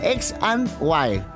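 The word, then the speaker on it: X&Y: que se llamaba XYE. XY. ¿En realidad cómo se dice? Ampersat. Bueno, X and Y, consolidando su estatus como una de las bandas más importantes del momento.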